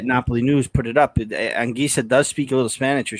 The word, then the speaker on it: Anguissa: The proper pronunciation of his name is Anguissa.